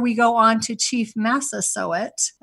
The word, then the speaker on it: Massasoit: Yes, Chief Massasoit. For the grandparents and parents and kids that are listening, just say sow it, like sow it up. It's Massasoit.